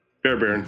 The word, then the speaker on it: Fairbairn: Fairbairn? – Fairbairn, yeah.